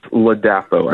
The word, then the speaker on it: Ladapo: And if I'm not sure about the pronunciation, is it Ladapo or Ladapo? – It is Ladapo, I believe.